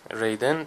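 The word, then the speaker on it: Raiden: در این بین کینو هم با اینکه فردی تبهکار، بی اخلاق و بی نزاکته، در کنار بقیه برگزیدگان وارد معبد رایدن میشه، که البته ریدن تلفظ درست این اسم هست ولی چون بعضی وقتا تو ایران با من رایدن میشه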